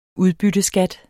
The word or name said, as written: udbytteskat